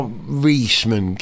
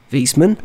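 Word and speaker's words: Wiesmann: W-I-E in German. The second of the vowels is the one you pronounce. So I-E is E, E-I is I. – Right. And who the heck are Weissman?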